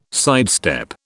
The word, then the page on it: sidestep: British English